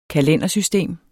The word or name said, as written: kalendersystem